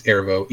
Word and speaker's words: Erivo: I think it's Ervo. E-R-I-V-O.